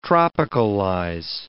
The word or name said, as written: tropicalize